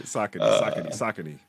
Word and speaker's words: Saucony: When you pulled that out, I was like, okay, how do y'all pronounce? Because I always said Saucony, but you said Saucony. Is there a proper pronunciation? – So it's Saucony.